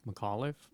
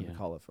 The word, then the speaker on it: McAuliffe: And then how do you say Terry's last name? – McAuliffe. – Is it McAuliffe? Okay, yeah. McAuliffe.